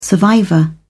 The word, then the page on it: survivor: British English